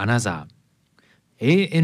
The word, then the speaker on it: ANOTR: さてオランダはアムステルダム出身の2人組デュオアナザー、 ANOTR と書いてアナザーと読みます。